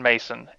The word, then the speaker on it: Maison: You can find me on Twitter at Jordan Mason, MAISON.